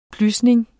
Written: plysning

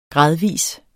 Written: gradvis

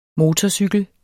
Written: motorcykel

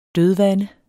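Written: dødvande

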